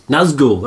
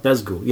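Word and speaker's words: Nazgûl: Nargol out of the sky. That's pretty good. A flying Nargol, or Nargul, how do you pronounce it? – Nazgul, that's what I'm thinking of. Nazgul, you know what I'm trying to say.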